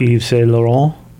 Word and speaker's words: Yves Saint Laurent: Yves Saint Laurent.